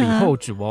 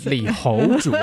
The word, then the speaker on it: 李后主: 李后主哦，不是李侯主哦，大家要这个发音要发清楚。